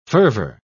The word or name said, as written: fervor